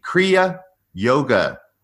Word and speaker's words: Kriya Yoga: in the Kriya Yoga tradition.